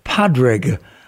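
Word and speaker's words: Pádraig: they don't necessarily know everything about Patrick himself or Padraig, I believe, as it is spelled and pronounced in Irish or Gaelic.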